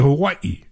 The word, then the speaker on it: Hawaii: Hawaii, because you have to pronounce it correctly. Hawaii, which is, by the way, how they want you to pronounce it.